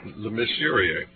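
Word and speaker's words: Lemesurier: Is that authored by Peter Lemessurier? – Lemessurier. Right. – Oh, that's a French pronunciation.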